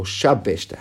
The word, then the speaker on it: shabeshta: Or shabeshta. How do you pronounce it? Shabeshta.